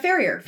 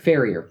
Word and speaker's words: Ferrier: Ferrier. – Ferrier. – Mm-hmm. – Okay. I wasn't sure if it was French. I mean, I'm sure it probably derives from French, but... Not, it's got an Americanized pronunciation.